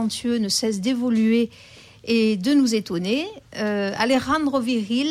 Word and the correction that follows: Alejandro Vigil: je ne sais pas si je le prononce bien. – Hein. Alejandro Vigil. Alejandro Vigil.